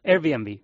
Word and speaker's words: Airbnb: A ver si lo digo bien, porque mi inglés es el que es, no es otra cosa. Airbnb, ¿puede ser? – Bueno, Airbnb, bien.